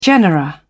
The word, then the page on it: genera: British English